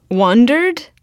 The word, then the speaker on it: wandered: Wandered? – Yeah. – Wandered. – So, yeah. So the first syllable, we tend to say higher, unless we're asking a question, which you did the first time, right? – Wandered?